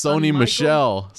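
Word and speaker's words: Sony Michel: It's not Sonny Mitchell. – What the hell's his name? – It's Sony Michelle. Sony Michelle, man. It's not Sonny Mitchell. – Sony Michelle.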